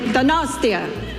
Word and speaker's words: Donostia: Donostia.